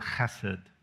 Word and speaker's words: chesed: It's the word, the Hebrew word chesed. We usually spell it like H-E-S-E-D, but the H is kind of a guttural H. Chesed.